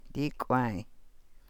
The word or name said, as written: dii kwaay